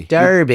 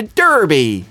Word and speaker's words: Derby: I was going to say, well done for pronouncing it correctly. – Derby. – You didn't do the classic American thing of, I'm going to Derby.